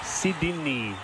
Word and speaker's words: Sydney: Sydney. – No, he took one out. – No, play it again. – Say the word Sydney. – Sydney. Two syllables.